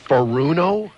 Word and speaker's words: Furuno: Furuno? – Mm-hmm. – It doesn't sound Japanese. Spell it. – F-U-R-U-N-O. – I can say it. Furuno.